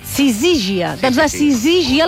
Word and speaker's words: sisígia: Ja ho havíeu dit, que es deia sísigia. – No. – A la segona. – Per tant, Sisígia.